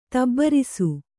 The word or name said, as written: ತುಬ್ಬೇರಿಸು